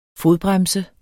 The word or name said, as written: fodbremse